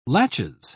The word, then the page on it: laches: British English